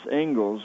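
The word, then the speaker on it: Inglis: A very, very famous Harvard professor for whom the honor lecture in education at Harvard is named, Alexander Ingalls, although it looks like it's English, it's pronounced Ingalls.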